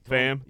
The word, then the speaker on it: Pham: Pham.